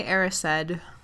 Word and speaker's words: Erised: so, on to chapter 12, The Mirror of Erised. How do you say it? – Yeah, in the films they say Erised.